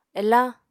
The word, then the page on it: elañ: Apatani